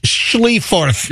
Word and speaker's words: Schleiffarth: I love this name. S-C-H-L-E-I-F-F-A-R-T-H. Schlieffarth. – Yes, that's a silent H.